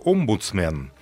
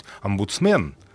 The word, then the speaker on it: омбудсмен: Он произнес два раза слово «омбудсмен». Меня это сначала задело, потому что мне привычнее это слышать «омбудсмен», вот, а как раз тут лежит рядом словарь, я смотрю в словаре, и тут дается ударение на первом слоге.